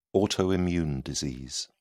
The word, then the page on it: autoimmune disease: British English